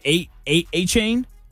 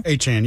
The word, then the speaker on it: Etienne: Travis Etienne's last name is apparently pronounced A-Chain. A-Chan now? – A-Chan, yeah.